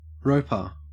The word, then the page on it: roper: Australian English